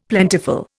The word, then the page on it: plentiful: Indian